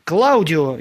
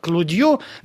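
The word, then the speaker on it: Claudio: Клаудио, его итальянское имя французы безжалостно произносят как Клудио,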